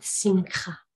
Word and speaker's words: simcha: In the Hebrew, the Old Testament word is spelled S-I-M-C-H-A, and that's pronounced simcha with a guttural ch at the end.